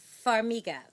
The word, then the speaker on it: Farmiga: Vera Farmiga.